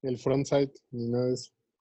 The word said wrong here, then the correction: Fortnite: El Fortnite.